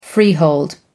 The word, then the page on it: freehold: American English